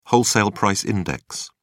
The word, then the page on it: wholesale price index: British English